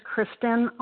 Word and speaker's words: Kristen: My name is Kristen R. I spell it K-R-I-S-T-E-N,